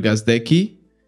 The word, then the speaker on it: Gazdecki: We are live with Andrew Gazdecki.